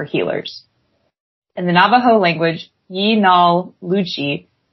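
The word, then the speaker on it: yee naaldlooshii: In Navajo culture, a skinwalker is and the term for it is pronounced Yinalushi.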